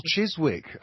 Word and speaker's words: Chiswick: Because I was out in Chiswick, staying out. – Oh, Chiswick. Oh, right, lovely. – I said it right. – Yes, you did.